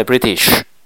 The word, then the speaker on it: British: 就必定跌，必跌，必跌，必定跌落嚟噶啦，跌落嚟经济唔好咪食薯仔咯，咪 British 咯，British，必定跌落嚟就食薯仔啦， British 咁啦，正確發音係 British，容易記就 British 咁啦。